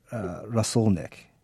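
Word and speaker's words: rasolnik (рассольник): rasolnik.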